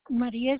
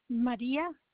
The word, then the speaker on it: Maria: Maria Espinosa. – Who? – Maria Espinosa. – Can you spell it? – What? – Can you spell your name? – My name? Okay, it's Maria. M-A-R-I-A. Maria Espinosa, E-S-P-N-O-Z-A.